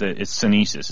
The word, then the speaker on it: sinensis: Okay, so we've got cordyceps, and it's senesis is how you pronounce correct. – That, right? – Correct, that's right.